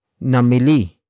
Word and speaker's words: namili: The first word is namili, namili, namili. Namili is spelled as N-A-M-I-L-I.